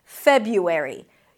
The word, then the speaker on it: February: Now, you can drop actually the first R and pronounce it as Feb-U with a Y instead, February. This is what some people didn't like, February.